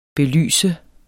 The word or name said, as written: belyse